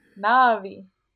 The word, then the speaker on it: Na'vi: Na'vi.